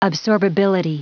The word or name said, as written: absorbability